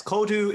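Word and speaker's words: Códú: Yes, Kodu is Irish for coding. So I actually knocked off a middle accent. It's meant to be C-O Fada, D-U Fada.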